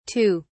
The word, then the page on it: tue: British English